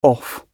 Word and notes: off: British English

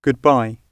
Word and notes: goodbye: British English

